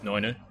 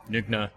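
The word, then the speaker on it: Nøgne Ø: If in Danish, they generally don't pronounce their words as much, so it's Noinu, which means naked island, by the way. – Yeah. – And then, you've got Nugna, which would be the Norwegian way of saying it.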